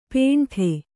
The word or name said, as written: ಪೇಂಠೆ